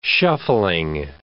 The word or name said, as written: shuffling